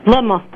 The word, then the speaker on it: Bluma: Luma. – Can you spell it for me? – B-L-U-M-A. – B-U-L-M-A, Bulma. – B-L-U-M-A.